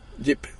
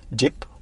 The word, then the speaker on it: Jeep: Estoy muy agradecido de que estemos en tu Jeep o Jeep, o cómo lo pronuncias esto. Jeep. Jeep. Renegade.